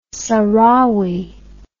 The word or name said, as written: sahrawi